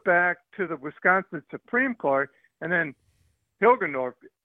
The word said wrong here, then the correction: Hagedorn: or whatever his name is, Hilgeborn, or Hagedorn. – I might get the name wrong. – Hagedorn. – Yeah.